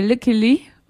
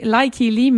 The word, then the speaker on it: Lykke Li: C'était la pièce « Until We Bleed » de Clear Up avec la voix de Lucky Lee. En fait, on aurait tendance à prononcer « Lucky Lee », mais comme c'est une chanteuse suédoise qu'on entend sur cette pièce-là, il faut prononcer « Lucky Lee ».